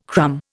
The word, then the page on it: crumb: Indian